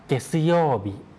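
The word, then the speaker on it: เก สิโย บิ (げつようび): เกสิโยบิเกสิโยบิโรมันจินะครับเขียนเป็นตัว G ตัว E นะครับออกเสียงว่าเกตัว TSU นะครับออกเสียงว่าซึ YOU ออกเสียงว่าโยนะครับแล้วก็ตัว B ตัว I ตัวนั้นออกเสียงว่าบิเกซึโยบิตรงโยเสียงยาวนะครับนอกนั้นเสียงสั้นหมดเลย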